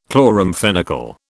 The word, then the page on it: chloramphenicol: British English